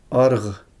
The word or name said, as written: arʁ